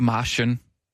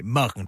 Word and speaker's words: Marken: Udtales ikke Marschen. Nej, Marken, det er øh, Marken. – Et, et hollandsk navn.